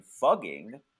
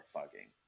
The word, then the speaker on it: Fugging: So a campaign to change the village's name to Fugging, right? F-U-G-G-I-N-G, which actually it would be pronounced the same way in the local dialect, right?